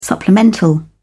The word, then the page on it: supplemental: British English